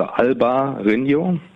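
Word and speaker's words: Albariño: Ähm, und äh, spricht es auf Spanisch aus, also Alba Rino, Alba, weiß, mhm.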